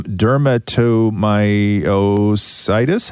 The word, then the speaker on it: dermatomyositis: dermatomyositis."